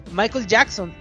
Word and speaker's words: Michael Jackson: Michal Jackson. Ah, Michael Jackson.